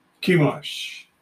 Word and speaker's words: Chemosh: Okay, so that phonetically is, let me just be sure that I've got it here. So if I tell you it's correct, as if he saves is, Chemosh.